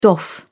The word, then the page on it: doff: British English